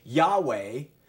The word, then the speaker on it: Yahweh: And what that means then is, I'm not going to get too into this, but just let you know, we don't really know how to pronounce this word because we don't have vowel markings. Now, the Hebrews have markings that they put between the consonants of their letters, and it would help you understand how to pronounce the words. But we don't have vowel markings for the YHWH. We will say Yahweh, or we will say Jehovah, but that is the proper name for God, and it's really not known exactly what it is.